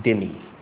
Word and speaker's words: dhimmi: You know, for many centuries, the Christians in the Middle East lived as dhimmi, or this is usually in English pronounced dhimmi. The word is D-H-I-M-M-I, that's the spelling.